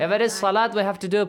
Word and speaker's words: salat: Or salat. It will be pronounced as salat. Waw on the top of waw, there is dagger alif, not salawat.